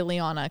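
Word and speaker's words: Liana: People always say Leanna because it's one N, L-I-A-N-A.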